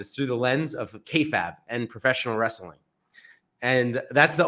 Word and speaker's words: kayfabe: So just to correct you, it's pronounced kayfabe. Kayfabe.